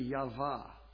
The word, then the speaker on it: Yahweh: Maybe they took a little bit of Hebrew or something and found out that, well, that W can be pronounced like a V. It's really Yahvah.